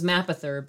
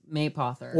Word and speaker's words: Mapother: I thought it was Mapother, but apparently it's Maypother.